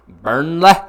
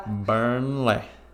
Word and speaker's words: Burnley: Oh, I didn't even say it right. Sorry. Burnley. – Burnley.